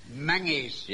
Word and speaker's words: Menzies: Now, quite out of the blue, we've received an invitation from Major General Menzies. – Mengies. – Yeah, I beg your pardon? – It's pronounced Mengies.